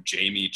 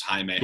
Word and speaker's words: Jaime: Let's place bets, Bill, on, you know, the percentage of people in Alabama who just call him Jamie Jaquez instead of how you actually pronounce his name, which is Jaime.